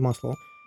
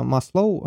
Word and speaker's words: Маслоу: Кто хоть как-то где-то занимался психологией или маркетингом, я уверен, вы 100% слышали про пирамиду Маслоу. Фамилия, кстати, правильно произносится Маслоу, а не Маслоу.